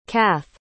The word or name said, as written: cath